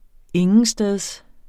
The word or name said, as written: intetsteds